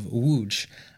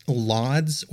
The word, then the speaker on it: Łódź: Before we go any further, I do want to mention that when I'm talking about this city of Łódź, I'm actually referring to what you may know as Lodz or Lodz or Lodge or however else you might pronounce the letters L-O-D-Z in English. That's not how it's pronounced in Poland, so I'm going to go with the Polish pronunciation, which hopefully I'm pretty close with. But I thought it was worth explaining to everyone, because I think Lodz is a much more common pronunciation in English.